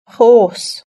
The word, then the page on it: horse: British English